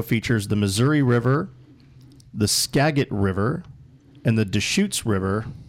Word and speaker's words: Skagit: And I will let you know that it's pronounced Skagit.